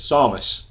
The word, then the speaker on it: Samus: It's a bit hard for Samus. – Yeah. – But you do it. – Samus, Samus. – Samus. – How do they say it in Smash Brothers? Samus.